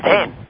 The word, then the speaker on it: Montaigne: I want to refer to a quote that you have in the book by Michelle de Montaigne, and correct me if I'm mispronouncing the person. – Montaigne, yes, indeed.